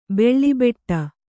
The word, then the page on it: ಬೀಳುಬಿಡು: bīḷu biḍu